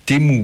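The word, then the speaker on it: Teemu: Alors, Temu, c'est T-E-E-M-U. C'est un peu comme le site chinois là où tout le monde fait ses courses. Là. Temu Brunila. B-R-U-N-I-L-A. Je suis désolé pour la prononciation. Temu Brunila à la télé.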